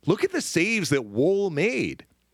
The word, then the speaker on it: Woll: They brought in Joseph Woll, which is how you now say his name.